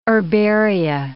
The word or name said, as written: herbaria